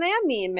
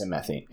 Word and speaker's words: amine: An amine. – I'm not going to go into that. You can Google that if you want. It's spelled A-M-I-N-E.